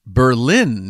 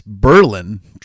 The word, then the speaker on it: Berlin: Saturday night, I went way up to, and I got schooled on how to say it. I would say Berlin, New Hampshire, but it's not. It's Berlin.